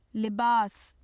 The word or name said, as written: libas